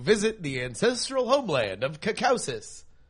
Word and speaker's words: Caucasus: Oh, Caucasus. – Yes. – Caucasus? I think that just means the land of white people. – I think so. – Caucasus.